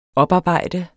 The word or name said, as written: oparbejde